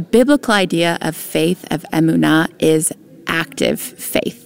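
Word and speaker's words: emunah (אמונה): Emunah, or emunah, more properly pronounced in Hebrew.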